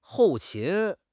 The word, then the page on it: 后勤: HSK6